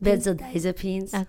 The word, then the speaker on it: benzodiazepines: I heard that benzodiazepine, did I say that right? Benzodiazepine? – Benzodiazepines.